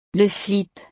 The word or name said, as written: le slip